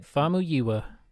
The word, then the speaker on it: Famuyiwa: Rick, and I'm probably going to butcher this, but Rick Famuyiwa. – Famuyiwa? – Famuyiwa.